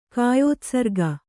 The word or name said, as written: ಕಾಯೋತ್ಸರ್ಗ